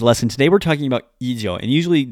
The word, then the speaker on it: 以上: Now, ijo, this is a long u sound, has a particular use, which is, two particular uses, and we should start with one and move on to the second.